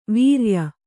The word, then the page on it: ವ್ರಯ: vraya